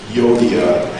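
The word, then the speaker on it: Euodia: You know, Nancy did such a great job of reading and pronouncing Yodia and Syntyche. I struggled with the pronunciation, and I finally came up with odious and soon touching because these two ladies are arguing and they're having trouble, and because of their arguments, the church is not of one mind.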